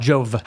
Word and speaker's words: Jove: Jova or Jova? – Jove. – How do you spell it? – J-O-V-E.